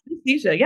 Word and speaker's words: synesthesia: Yeah, synesthesia.